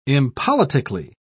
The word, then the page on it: impoliticly: British English